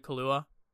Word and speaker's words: Kaluuya: And yeah, the moments like that when he's speaking, I mean, Daniel is it Kaluuya? – Yeah, that's how I always pronounce it.